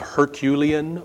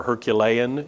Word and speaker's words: Herculean: Incredibly strong guy, according to Greek stories, brings us the adjective Herculean or Herculean. You can pronounce it either way if you like.